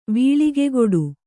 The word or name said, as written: ವೀಳಿಗೆಗೊಡು